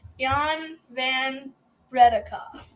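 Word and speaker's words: Jan van Breda Kolff: I had Jan Van Bredekhoff.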